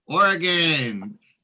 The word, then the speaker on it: Oregon: Oregon. That's the right way to pronounce it, too. – Yeah. – My friend is from Oregon, and we used to pronounce it Oregon when I was growing up.